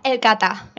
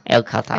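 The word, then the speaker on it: El Cata: Did I pronounce it correctly, El Carter? E-L? – El Carter. – El Carter.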